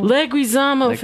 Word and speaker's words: Leguizamo: Leguizamo.